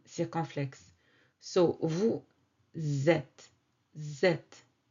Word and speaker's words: vous êtes: Usually, vous with that S, the S here is silent. And it is not pronounced, but when the following word starts with a vowel, like E accent circonflexe, both of them will link together through the pronunciation we call la liaison.